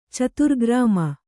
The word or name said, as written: ಚತುರ್ಗ್ರಾಮ